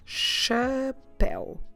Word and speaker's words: chapéu: The word for hat is chapéu. Chapéu. Como se escreve chapéu? C H A P E com acento U.